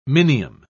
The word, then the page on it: minium: British English